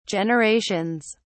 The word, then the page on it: generations: British English